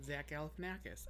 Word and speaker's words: Zach Galifianakis: Okay, that's how you pronounce it, Zach Galifianakis.